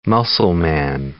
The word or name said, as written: muscleman